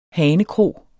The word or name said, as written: hanekro